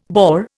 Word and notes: Boer: Indian

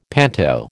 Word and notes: panto: American English

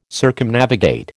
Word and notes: Circumnavigate: American English

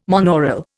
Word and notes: monaural: Indian

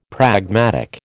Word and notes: pragmatic: American English

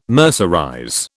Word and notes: mercerize: British English

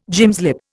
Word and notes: gymslip: Indian

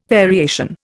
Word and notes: variation: Indian

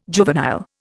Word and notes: juvenile: Indian

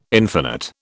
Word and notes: infinite: British English

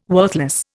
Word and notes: worthless: Indian

